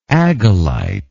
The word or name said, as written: agalite